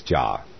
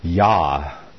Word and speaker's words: Yah: instead of where in the King James it says Jah, but nobody says Jah. His name is Yah, as in Hallelujah.